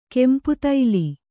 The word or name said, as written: ಕೆಂಪುತೈಲಿ